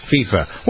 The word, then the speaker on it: FIFA: Is pronounced I. F-I-F-A is FIFA. I. Pronounced like Y. – Okay. – If you want to pronounce it FIFA, you spell it F-E-E-F-A.